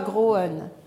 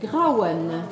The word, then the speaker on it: Grauen: Ne dites pas Groen. Grauen.